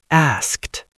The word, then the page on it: asked: American English